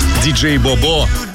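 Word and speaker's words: DJ BoBo: диджей Бобо. Вот именно так произносится имя этого исполнителя.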